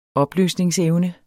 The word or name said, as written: opløsningsevne